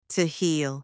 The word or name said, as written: to heal